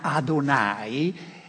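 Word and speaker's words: Adonai (אדוני): Para nosotros, Señor, es una palabra bastante débil, pero para una persona de la Biblia, pronunciar este nombre que en hebreo suena Adonai es algo muy, muy, muy sagrado.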